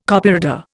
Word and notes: Copyreader: Indian

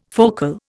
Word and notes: focal: Indian

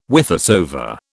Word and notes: whithersoever: British English

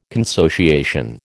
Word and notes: consociation: American English

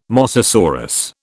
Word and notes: mosasaurus: British English

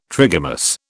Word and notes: trigamous: British English

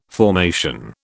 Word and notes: formation: British English

